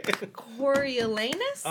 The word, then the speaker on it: Coriolanus: Coriolanus? – Uh-huh.